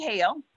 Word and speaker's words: Hale: And then you can also, I'm on the radio every Tuesday night from 6 to 8:30, Real Talk with Lady Hale. And my last name is spelled H-A-L-E.